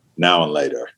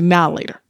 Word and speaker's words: Now and Later: Now and Later. – Now, that. Yeah. And notice that I pronounced it as Now and Later, but it's actually Now Later.